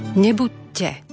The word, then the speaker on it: Nebuďte: Tam je potrebné predložiť dobu výslovnosti tejto spoluhlásky. Nebuďte. A teraz máme opäť po prvé Židom, po druhé Grékom, po tretie Božej cirkvi.